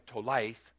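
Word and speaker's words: tolaith (תולעת): It comes from, the worm is, it's spelled in Hebrew, T-O-L-A-I-T-H, tolaith.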